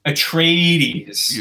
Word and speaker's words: Atreides: Atreides. Yeah, like a trade.